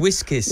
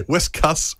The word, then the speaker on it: Whiskas: You said whiskers. – Whiskers, did yeah. I? Whiskas.